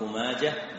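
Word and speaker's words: ماجه: يا صاحي نطق ماجة بالها بلا لجاجة، يا صاحي نطق ماجة بالها بلا لجاجة، يعني كلمة ماجة تنطق بالها ولا يقال ماجة.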